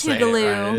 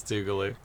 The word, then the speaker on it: Tougaloo: I'm saying it right. It is Tougaloo. – It is Tougaloo.